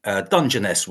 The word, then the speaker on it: Dungeness: Now, I know you reviewed this earlier, Dungeness, we call it. There's a soft G, by the way.